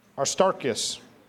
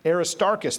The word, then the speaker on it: Aristarchus: So the city was filled with confusion, and they rushed together into the theater, dragging with them Gaius and Aristarchus. Oh, I'm saying it wrong. Aristarchus, thank you.